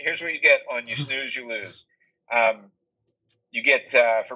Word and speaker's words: snus: This first one is called You Snooze, You Lose. – Okay. – And snooze is spelled S-N-U-S.